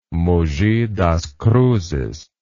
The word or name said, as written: Mogi das Cruzes